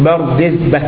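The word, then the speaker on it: بردزبه: التي لها نقطة واحدة يعني، وسكون الراء المهملة، يعني ليست زاي المهملة بخلاف المعجمة، وكسر الدال بردي المهملة، وسكون الزاي بردز المعجمة، وفتح الباء الموحدة بردز بعدها هاء، هكذا تنطق بردز به.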